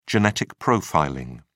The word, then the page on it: genetic profiling: British English